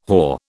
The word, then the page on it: hoar: British English